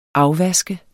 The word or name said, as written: afvaske